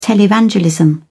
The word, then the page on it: televangelism: British English